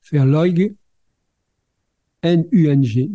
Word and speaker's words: Verleugnung: qui est le mot alors ça s'écrit V-E-R L-E-U-G Verleug N-U-N-G Nung. Verleugnung. Nung.